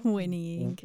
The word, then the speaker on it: whinnying: Some horse weaning. What is it? – Whinnying. – Whinnying. – Whinnies. – Horse whinnies. – But whinnying is, I guess, how you would say it.